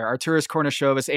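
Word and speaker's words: Artūras Karnišovas: Arturis Kornishovas and the organization have been resistant to doing anything other than being on that hamster wheel of mediocrity.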